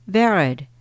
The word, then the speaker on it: Varad: I've learned to say, "Varad," rhymes with Jared, but with a V.